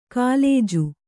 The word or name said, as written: ಕುಲಜ